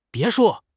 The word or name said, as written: 别墅